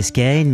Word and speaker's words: Skagen: Alors la série s'appelle Skane, prononcé Skane, même si ça s'écrit en français Skagen.